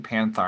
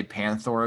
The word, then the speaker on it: Panthor: Because you keep saying Panthar, and I always thought it was Panthor. – Oh, I might just be wrong. – You can't even Google that. How do you pronounce Panthor? – Yeah. How's it spelled? – P-A-N-T-H-O-R is Panthor.